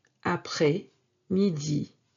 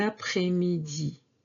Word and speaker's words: après-midi: And yes, this S is not pronounced. Bon après-midi. Bon après-midi.